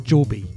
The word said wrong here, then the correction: Jaubi: I think it's pronounced Jaubi, actually, Jaubi.